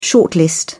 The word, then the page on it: shortlist: British English